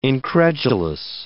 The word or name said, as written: incredulous